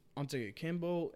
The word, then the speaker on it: Antetokounmpo: So, I right now, with Antetokounmpo and...